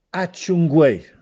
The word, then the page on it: Acciuccuè: Castellanetano